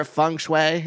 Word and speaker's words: feng shui: Again, not enough new agey or feng shui.